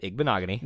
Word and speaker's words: Igbinoghene: So if you had told me Noah Igbinoghene, and I said it right, Igbinoghene. – You got it.